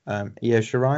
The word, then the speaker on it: Io Shirai: I mean, I don't know if I think her feud with Io Shirai, am I saying that right? – Yeah.